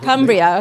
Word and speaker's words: Cumbria: Cumbria, how do you say it? Cumbria, okay.